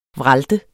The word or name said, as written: vralte